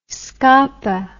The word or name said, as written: scapin